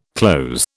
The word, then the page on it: cloze: British English